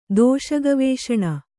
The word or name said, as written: ದೋಷಗವೇಷಣ